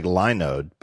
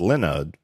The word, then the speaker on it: Linode: Looks like Linode, but it's pronounced Linode because they specialize in Linux server hosting.